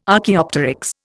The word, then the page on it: Archaeopteryx: Indian